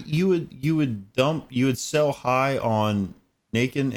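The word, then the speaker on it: Naquin: Sell high right now on Nakin. – Is that how it's pronounced? – Knockin. It's knockin. – It's French.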